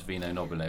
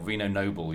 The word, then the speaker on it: Vino Nobile: I mean, it's one of those Italian phrases that's quite easy for people to pronounce, Vino Nobile, or Vino Noble, you could call it if you yes. – wanted to Americanize it.